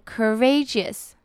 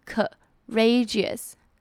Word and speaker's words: courageous: 那如果要形容一个人非常有勇气，非常非常英勇，这个形容词就是用 courageous, courageous。那它的发音又不太一样，它不是直接 cur 的音，它是 courageous, courageous。 C O 这边呢是发 e 的音，courageous。